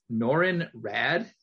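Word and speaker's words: Norrin Radd: She calls him by his real name, which is Norrin Rad. Am I saying that correctly? – Yes.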